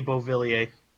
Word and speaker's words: Beauvillier: Bouvier. – Oh, uh, Anthony Beauvillier. – Beauvillier.